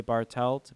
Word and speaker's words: Bartelt: Bartelt, B-A-R-T-E-L-T.